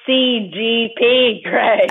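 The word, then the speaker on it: CGP Grey: It's not CGP Gray. CPG Gray, is that right? – It's CGP Gray. – Oh, I said it right the first time.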